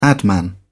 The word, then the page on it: adman: British English